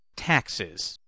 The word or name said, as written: taxes